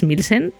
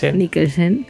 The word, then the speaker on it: Mikkelsen: y la parte de, Clifford Anger, de, del Mats Milsen, se lo he dicho bien. Mikkelsen.